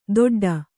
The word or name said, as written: ದೊಡ್ಡ